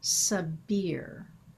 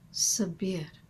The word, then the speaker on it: Sabir: Murshid Hidayat presents his wazifa spelled S-A-B-I-R. Now, in English pronunciation, we tend to say Sabir. Yet in Arabic, the pronunciation sounds like Sabir, which transmits a quality of air in the vowel sound of the last syllable. And it closes with this soft R that rolls from the breath of that vowel. Sabir.